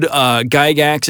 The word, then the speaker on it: Gygax: The original D&D system, I think everybody agrees, was jointly developed by two guys named Gary, I don't know how to pronounce his last name. Gygax.